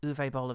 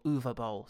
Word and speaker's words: Uwe Boll: What video game should Uwe Boll have done next? Or Uwe Boll, sorry, I should use the correct pronunciation that we've subsequently learned.